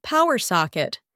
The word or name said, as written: power socket